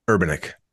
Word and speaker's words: Urbanek: Urbanek.